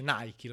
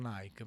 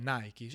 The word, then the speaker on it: Nike: Eh, Nike si dice Nike, lo so che in Italia è diventato Nike, però è Nike.